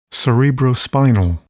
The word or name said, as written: cerebrospinal